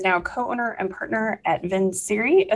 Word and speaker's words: Vincere: Okay, Vinciri. I was going like the Italian version. – It's Latin and it totally doesn't sound like that, but everyone goofed it up. So that's the way that it's pronounced now.